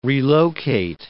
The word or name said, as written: relocate